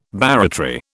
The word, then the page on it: barratry: British English